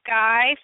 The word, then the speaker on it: SCCY: Sky Firearms, huh? – SCCY. – What is it? – SCCY. It's pronounced Sky.